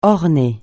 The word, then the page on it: orner: francais